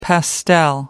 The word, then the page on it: pastel: American English